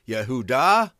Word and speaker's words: Yehuda: Which Bible concordance you pick, but nevertheless, the fact is there was no J in the Hebrew language, so that's why it's pronounced Yehud, Yehuda, Yuda, Yehudi, etc., etc. It's never pronounced with a J.